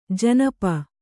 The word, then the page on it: ಜನಪ: kannada